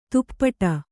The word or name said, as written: ದುಪಟಿ